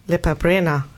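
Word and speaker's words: Lepa Brena: Nämlich Lepabrena. Habe ich es richtig ausgesprochen eigentlich? – Ja.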